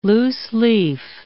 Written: loose-leaf

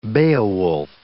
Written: Beowulf

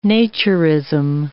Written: naturism

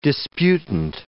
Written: disputant

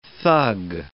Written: thug

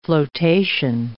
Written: flotation

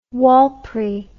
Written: Warlpiri

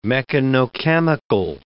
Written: mechanochemical

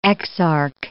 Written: exarch

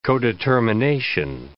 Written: codetermination

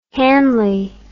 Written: Hanley

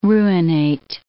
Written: ruinate

